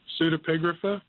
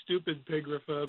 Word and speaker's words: Pseudepigrapha: Pseudepigrapha. – Pseudepigrapha. Yeah, there we go. I knew I'd be close. – Pseudepigrapha. Funny, people say stupid pigrapha, but pseudepigrapha.